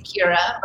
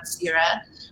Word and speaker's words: Ceara: So it's Gaelic and two syllables. So if I am in Ireland, they say Ceara, but my parents decided on Ceara, like the goddess of grain series, and I was called cereal in kindergarten a lot.